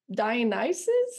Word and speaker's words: Dionysus: And I thought that I'm going to pronounce it wrong, even though Kami just said it, but Dionysus? – Yeah. – Oh, thank goodness. That never happened. My entire childhood, I said it in my head, Dionysus.